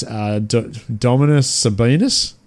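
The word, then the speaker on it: Domantas Sabonis: Dominus Sabonis? Is that how you say it? Nailed it.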